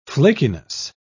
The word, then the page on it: flakiness: British English